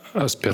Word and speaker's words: Аспергера: О трупе комиков, у которых один и тот же диагноз, по-моему, болезнь Аспингера. Я правильно говорю? – А, Аспергера. Аспергера.